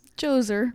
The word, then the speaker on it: Djoser: Djoser. – Yeah, Djoser. It's spelled with a D-J-O-S-E-R.